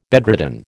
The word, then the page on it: bedridden: American English